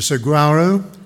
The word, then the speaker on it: saguaro: So, a little over a week ago, walking through the Desert Botanical Gardens in Phoenix, I learned that the Sagaro, if that's the way you pronounce it, the, cig- the saguaro cactus have little pores in their skin that collect carbon dioxide.